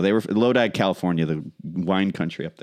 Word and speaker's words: Lodi: I think it's pronounced Lodi. It just made me think of it. – Yeah, Lodi.